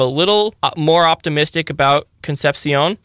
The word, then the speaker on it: Concepcion: He put Conception. – Well, that's how it's spelled. – Oh, sorry. Oh, yeah, KC Conception. Yeah. – Yes, that's how it's spelled, but it's not pronounced that way.